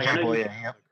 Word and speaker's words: Bouye: And even, is it Bouye? How do you pronounce his name? – I think it's Bouye, but I'm not going to. – Bouye? Yeah, Bouye.